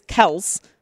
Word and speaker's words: Kelce: So he said the confusion is their father, Ed, got tired of correcting everybody at work who called him Kelce. The correct pronunciation is Kelce, like he just said. But they've gone by Kelce their entire lives.